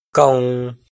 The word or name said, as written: koo